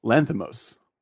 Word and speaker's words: Lanthimos: What's his last name? – Lanthimos. – Lantimos. – Yep. – Lantimos. Yorgos Lantimos.